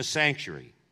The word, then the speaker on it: sanctuary: Sanctuary, you say, how do you say it in Australia, in British English? We say sanctuary. It's like got an extra syllable in there.